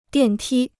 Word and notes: 电梯: diàn tī

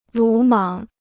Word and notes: 鲁莽: lǔ mǎng

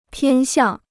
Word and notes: 偏向: piān xiàng

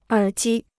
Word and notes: 耳机: ěr jī

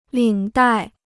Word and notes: 领带: lǐng dài

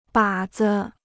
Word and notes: 靶子: bǎ zi

